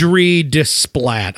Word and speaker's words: Alexandre Desplat: Alexandre Desplat. I'm sure that's the official pronunciation.